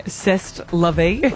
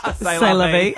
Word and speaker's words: c'est la vie: I hope so, because remember you kept saying zest la vie, which is actually pronounced c'est la vie.